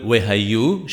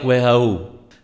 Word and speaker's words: Wehayu: Similarly, the Yod in the word Wehayu should be emphasized correctly so that it doesn't sound like Wehau.